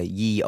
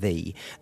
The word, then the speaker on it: Ye: So you know when you see a pub sign that says uh, "Ye Oldie Tavern" or whatever.